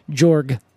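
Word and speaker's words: Georg: Oh, yeah, I believe it's pronounced Jorg.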